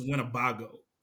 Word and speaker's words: Winnebago: It's pronounced Winnebago, for the record.